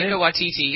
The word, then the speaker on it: Taika Waititi: Anyway, is it pronounced Taika Waititi? Is that Taika Waititi, yep.